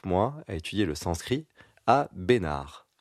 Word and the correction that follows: Bénarès: Bénarès, puisque c'est le dans le sud. – On prononce toutes les lettres, monsieur Bénarès.